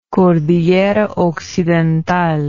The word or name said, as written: cordillera occidental